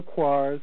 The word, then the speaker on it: Koire: You can read Rosa Quare's, some people pronounce it Quare, K-O-I-R-E, Rosa Quare, Behind the Green Mask.